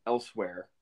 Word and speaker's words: Elsweyr: Um, now, elsewhere, or elsewhere, I think it, it's pronounced elsewhere. But I always used yeah, I think it's just like saying the word elsewhere.